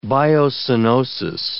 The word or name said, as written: biocoenosis